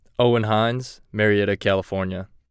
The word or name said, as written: Owen Hinz